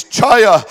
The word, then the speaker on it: chaya: It's, and I'm going to give you the English pronunciation to the best of my ability. It's chaya, and it means to live or to cause to live, to quicken or to live again.